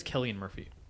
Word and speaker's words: Cillian: Apparently it's Killian Murphy.